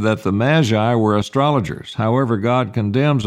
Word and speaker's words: magi: Or in the Greek, magi is the proper word and pronunciation.